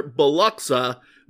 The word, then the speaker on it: Biloxi: Or as I learned while we were there, Biloxa, Mississippi. Fascinating the way places decide to pronounce the names of where they are, and it doesn't match the spelling at all.